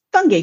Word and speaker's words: Fotheringay: But in British English, it's pronounced Fungi Phipps.